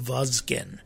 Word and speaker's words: Vazgen: And so I went with the K spelling, but, you know, the third letter definitely is a Z. There's no S there. It's a Vazgen. And, the K also, I agreed with that because usually the G we could say with a hard G sound, but it doesn't really translate that well, does it? Because there's a soft G sound, so it could be Vazgen. And I didn't want to get into that, but I like the hardness of the K, Vazgen.